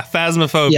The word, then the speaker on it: Phasmophobia: I was lucky enough to attend his, is it pronounced Phasmophobia? – Yeah, Phasmophobia. – Yes.